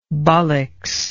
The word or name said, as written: bolix